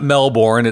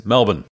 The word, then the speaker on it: Melbourne: It's not Melbourne. It's Melbourne.